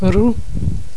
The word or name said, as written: roue